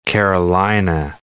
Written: Carolina parakeet

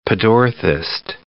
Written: pedorthist

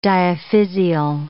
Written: diaphysial

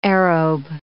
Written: aerobe

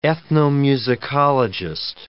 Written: ethnomusicologist

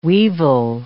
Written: weevil

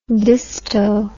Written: disto

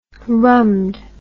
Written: rummed

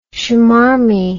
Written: shmarmy

